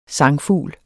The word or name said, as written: sangfugl